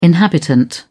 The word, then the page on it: inhabitant: British English